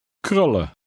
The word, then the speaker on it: krullen: Remember to continue to drop the N in the EN ending. Read number 8. – Krullen.